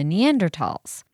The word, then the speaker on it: Neanderthal: So that must be where they found the bones that we now know came from the Neanderthals. – Yeah. – But what about the spelling? – The reason that they are spelled with a TH and pronounced tall with a hard T, that is something even more complicated, which is language reform.